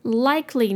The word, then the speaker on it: likely: Likely, 你可以想象那个 E 不存在。 Like, 后面直接加 L-Y. Likely. Likely.